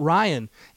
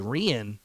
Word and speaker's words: Rian: And by the way, it's not Ryan. It's Rian.